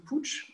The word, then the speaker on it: Puig: Alors, ça s'écrit Puig, mais ça se dit Puig, qui est un groupe catalan spécialisé dans les parfums, essentiellement, et qui a compris que...